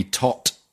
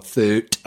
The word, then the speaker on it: Thoth: It's T-H-O-T-H. It could be Tot or Thut. – I like Thoth.